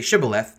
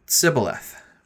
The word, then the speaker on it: shibboleth: They did not say shibboleth, they pronounce the word Sibboleth.